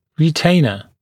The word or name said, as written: retainer